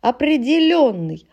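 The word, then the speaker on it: определённый: Ободренный, ободрен, ободрена, обостренный, определенный, отключенный, повторенный, поделенный. Это все ударение на букву Ё.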